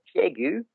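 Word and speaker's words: Piégut: Just north of Nontron is a town called Piegu, which is spelled like pie gut, which is most people mispronounce it. So P-I-E-G-U-T.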